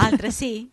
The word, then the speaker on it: altresì: No, non dice così. Dice. – Altresì? – Ecco. – Oh, sorry. – Guarda, accento. – Non mi riscrivere la lingua italiana, non mi rigirare Dante nella tomba. – Ops. Io altresì vi dico...